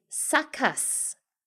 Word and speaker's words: succus: I did look up the pronunciation succus, and it's spelt S-U-C-C-U-S-S. So it looks just like success, except instead of an E at the end, it's a U. Succus. S-U-C-C-U-S-S.